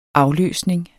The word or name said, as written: afløsning